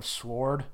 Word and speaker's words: S.W.O.R.D.: How do you say it? Sword? Yeah. Sword? Sword? – Yeah. – Sword? – Yeah, I don't pronounce the W at all.